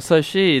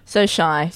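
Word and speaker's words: SoShy: Morning After Dark, Timbaland, featuring So Shy. Or So She. Is that how you pronounce it? – So Shy. So She's shy.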